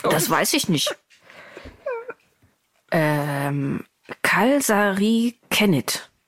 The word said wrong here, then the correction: kalsarikännit: Warte, ich habe ja auch einen Link zur Aussprache. Das muss ich mal eben an, anhören. Ich spiele das mal eben ab. – Kaasari kännit. – Kaasari kännit. – Kaas... Kaasari kännit. Mhm. Kaasari kännit.